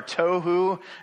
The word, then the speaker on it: tohu: The two terms that Moses uses here are tohu and bohu, which is kind of fun because they both rhyme with tofu, which tofu in the original Hebrew means tasteless, right?